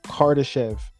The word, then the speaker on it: Kardashev: And to correct something too, that type 1 energy civilization I was talking about, it's pronounced Kardashev scale. I said it wrong a little bit, but the whole idea around it is correct.